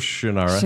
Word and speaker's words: Shannara: I can't say it right. – Yeah, Sword of Shannara.